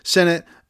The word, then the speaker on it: Sinnott: I believe I talked about 5 tight ends in the first show, which were Bowers, Sanders, Sinnott, Theo Johnson, and Jaheim Bell. And outside of those guys, I think there's only really one more guy I need to touch on this list. But anyway, Sinnott, S-I-N-N-O-T-T.